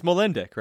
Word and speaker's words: Molendyk: Yeah, it's Molendyk, right? Molendyk, Molendyk, yeah, Molendyk. There we go.